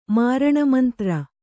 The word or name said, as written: ಮಾರಣಮಂತ್ರ